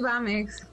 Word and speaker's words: BAMX: ¿Lo dije bien? ¿BAMX? ¿Así se pronuncia o BAMX? ¿Cómo estás? – Lo pronunciamos BAMX.